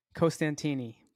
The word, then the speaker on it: Costantini: Costantini.